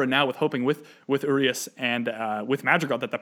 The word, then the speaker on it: Urias: I think it is Urias, like O-O, re, emphasis on that re and then is, but I think I've been calling him Urias a while.